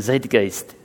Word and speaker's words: Zeitgeist: But you got to say it like a German, Zeitgeist.